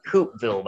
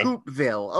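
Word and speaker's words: Coupeville: It's pronounced Coopville, by the way. – Coopville, okay.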